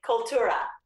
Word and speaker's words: Cultura: Cultura. Accent on the two.